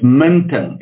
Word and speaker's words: Minton: It's Minton. It's a Dutch surname.